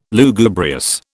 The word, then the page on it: Lugubrious: British English